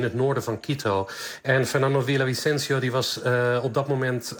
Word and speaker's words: Fernando Villavicencio: Presidentskandidaat Fernando Villavicencio, zo moet ik hem uitspreken, is vlak na een campagnebijeenkomst in de hoofdstad Quito doodgeschoten.